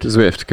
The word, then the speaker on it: Zwift: Come on, Swift. – Did I say it wrong? – No, Swift. – Zwift, cool.